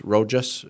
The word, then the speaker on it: Rojas: The manslaughter and criminal negligence charges against two Toronto General Hospital security guards, Amanda Rojas, how do you say R-O-J-A-S? Rojas? Rojas? – It's Rojas.